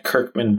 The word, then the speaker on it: Kirkman: Follow me at Kirkman Brandon, spelled like you think it is K I R K M A N B R A N D O N.